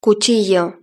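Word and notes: cuchillo: European Spanish

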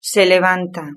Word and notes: se levanta: Spain Spanish

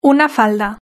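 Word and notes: una falda: Spanish (Spain)

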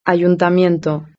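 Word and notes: el ayuntamiento: Spain

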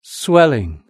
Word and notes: swelling: British English

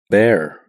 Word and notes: bear: British English

